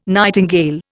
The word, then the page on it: nightingale: Indian